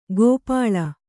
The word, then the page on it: ಗೋಪಾಳ: gōpāḷa